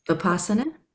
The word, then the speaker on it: Vipassana: And what is the, I'm not going to say it correctly, the Vipassana? – Yeah.